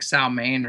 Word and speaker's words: Samhain: Halloween is people call it, I think it's Samhain, but they actually pronounce it like Salmain or something strange like that.